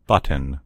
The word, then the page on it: button: American English